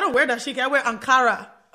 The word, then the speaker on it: Ankara: Ankara game crazy out here. – Ankara, thank you. And you said it right.